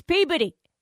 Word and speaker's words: Peabody: And I was like, dude, like, you know, like, why don't you just call up and say, hey, you, it's Peabody, just like it's Dorchester and Fall River and Somerville. Just be kind. Just tell me it's Peabody.